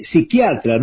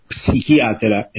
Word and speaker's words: psiquiatra: Se dice psiquiatra, no psiquiatra. La P no se pronuncia.